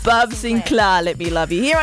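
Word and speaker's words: Sinclar: That's how you say it, yeah? Sinclair. – Okay. – Sinclair. There's no I, man.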